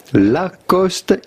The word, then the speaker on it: Lacoste: Lacoste in French, Lacoste.